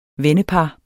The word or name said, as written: vennepar